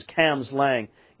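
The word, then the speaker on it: Cambuslang: The spelling is Cambus Lang, but the pronunciation is Cams Lang,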